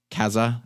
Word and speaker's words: Kasa: There are lots of other deals on products that are made by TP-Link or Kasa, as I believe it's pronounced, K-A-S-A.